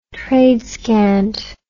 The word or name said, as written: tradescant